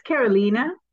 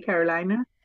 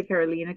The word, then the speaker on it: Carolina: Depending on what area of the country I'm traveling, sometimes I say Carolina. – Okay. – But as I got older, I say Carolina because it's just easier.